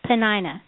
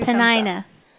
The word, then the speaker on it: Peninnah: The name of one was Hannah, and the name of the other, Peninnah. Did I say that right? – You did. You get two Peninnah.